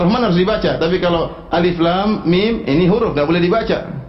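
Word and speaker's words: Alif, lam, mim (الم): Alif, lam, mim. Jangan dibaca alam, keliru. Jangan dibaca alam, ya.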